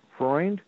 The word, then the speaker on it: Freund: Well, that's what it means in English, but it's the original German, Freund.